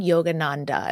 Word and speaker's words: Yogananda: So you mentioned Yogananda.